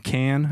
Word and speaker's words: Cannes: Cannes, I guess is how you say it. Yeah. I got my Italian and my French mixed up there.